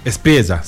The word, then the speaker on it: Espriella: Pero se hablaba en primera instancia de que Abelardo de la Espriella, eso lo dije bien, ¿no? – Espriella, sí.